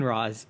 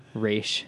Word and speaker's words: Ra's: Oh my god, they already brought in Ra's. – Yeah. You said it wrong. – Ra's. – There we go.